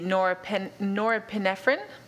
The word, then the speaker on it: norepinephrine: And it says that it's the norepinephrine.